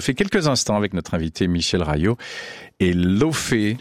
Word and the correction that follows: Laufey: Elle est islandaise, Leivey. – Ça se dit comme ça ? – Ça se dit Leivey. Ça s'écrit L-A-U-F-E-Y, Leivey.